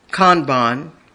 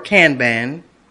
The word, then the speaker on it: Kanban: You'll hear Kanban or Kanban, which is spelled K-A-N-B-A-N.